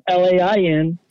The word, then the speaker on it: Lain: My name's Douglas Lain. My last name is spelled Lain.